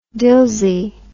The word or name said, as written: Dilsey